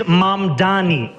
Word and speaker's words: Mamdani: And furthermore, the name is Mamdani. Mamdani. You should learn how to say it because we got to get it right.